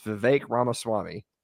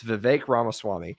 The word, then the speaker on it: Vivek Ramaswamy: I finally, now that his aspirations have ended, I finally learned his name. It's Vivek Ramaswamy. And I said it wrong until it didn't matter anymore, because that's just kind of how I roll. But it's Vivek Ramaswamy.